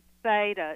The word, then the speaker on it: Veda: It's Veda. It starts with a V.